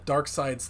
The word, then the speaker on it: Darkseid: And anyway, so Steppenwolf goes back to Darkseid's lackey. Which, by the way, you're right. It is pronounced Darkseid, even though it's spelled Darkseid.